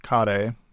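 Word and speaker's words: kare (カレー): So in Japan, there's something called curry, kare, is how they pronounce it.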